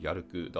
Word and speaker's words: YALC: はい、では続いて、えー、第 6 位は、えー、これ、やるくって読むんですかね。 YALC、やるくだそうです。